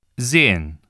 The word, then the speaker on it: sehen: When the letter S precedes a vowel or appears between two vowels, it is voiced or pronounced like the English Z. Listen and repeat. – Sehen, sieben, Sohn, sehr, suchen, lesen, Häuser, Musik.